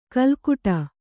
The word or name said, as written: ಕೆಲಕಡೆ